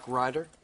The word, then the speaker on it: Ryder: My name is Mark Ryder. – Can you spell that, please? – R-Y-D-E-R.